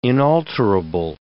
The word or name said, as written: inalterable